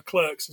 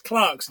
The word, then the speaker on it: Clerks: hard for me because I always want to call it Clark's because it's pronounced Clark's in the UK. We don't pronounce yeah. it Clerks.